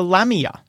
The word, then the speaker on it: lamia: known as a lamia. – Oh. – I don't know if I'm pronouncing that correct. – That's how I, as Dale, the mythology nerd, would pronounce it.